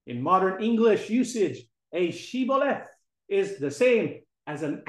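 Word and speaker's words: Shibboleth: The Ephraimites were exposed because they were unable to pronounce Shiboleth. Instead of Shiboleth, it became Siboleth. And so the term Shiboleth came into the English language.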